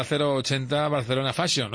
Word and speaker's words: 080 Barcelona Fashion: en esta 080 Barcelona Fashion. ¿Lo he dicho bien? – Lo has dicho bien, perfecto.